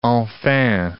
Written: enfin

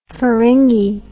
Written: feringi

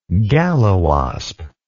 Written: galliwasp

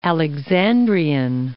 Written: Alexandrian

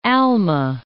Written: Alma

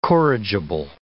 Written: corrigible

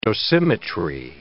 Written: dosimetry

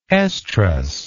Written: oestrus

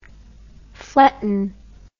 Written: fletton

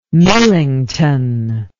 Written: Millington

